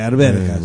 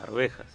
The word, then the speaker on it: arvejas: Bueno, pero ve, alberjas. Bueno, ¿cómo se dice? ¿Alberjas o arvejas? – Arvejas.